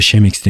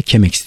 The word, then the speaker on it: Chemex: Yakınlarda Şemex demiştim ben. Fatih isimli bir dinleyici Almanların bulduğu bir demleme yöntemi ve Chemex diye okunuyor demiş.